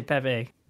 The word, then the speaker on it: Pepe: pepe how do you pronounce him actually i say pepe